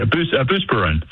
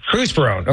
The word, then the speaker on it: Buspirone: I mean, she was on a boatload of Buspirone. Am I saying that correctly? Probably not. – Yeah, Buspirone. – Buspirone.